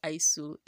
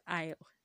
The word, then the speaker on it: aisle: It's not pronounced Isul. It is pronounced Isle. It is pronounced Aile.